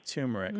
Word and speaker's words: turmeric: Turmeric, mm-hmm. Because there is an R there that many people pronounce.